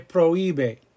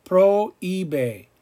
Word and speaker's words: prohíbe: Se prohíbe. Pro-íbe. Like eBay, right? Like eBay on the computer.